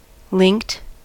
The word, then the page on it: linked: American English